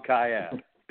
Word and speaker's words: Kiat: It's Tom Kiat.